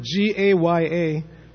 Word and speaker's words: Gaya: So the place where we go to offer the oblations to Pinda is Gaya with a long A.